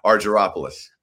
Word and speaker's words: Argyropoulos: See, I was going to go hard G, so I knew I was wrong. Argyropoulos. – Well, in all fairness, if we were in Greece, you would say you would use a hard G.